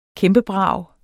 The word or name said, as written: kæmpebrag